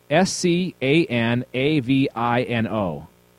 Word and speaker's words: Scanavino: The film starred Peter Skinivy. Hang on one second. Obviously, I can't pronounce anything. Peter okay, S C A N A V I N O. Scanavino.